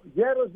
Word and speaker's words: γέρος: Το «γέρος» δεν είναι απλώς «γάμα» και «έψιλον» στα ελληνικά. Είναι γάμα γιώτα έψιλον όταν το προφέρουμε. Γιατί δεν μπορούμε, έχοντας το γάμα μετά το έψιλον, να προφέρουμε το γάμα έψιλον χωρίς το γιώτα μεταξύ τους.